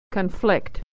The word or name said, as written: to conflict